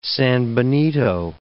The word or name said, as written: sanbenito